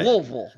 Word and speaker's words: Louisville: Louisville. – You got to say it like you got like a big wad in your lip or something.